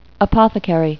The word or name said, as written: apothecary